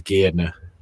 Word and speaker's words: Gairdner: I'm sure, obviously, someone's name, but the pronunciation apparently is Gairdner.